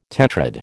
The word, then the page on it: tetrad: American English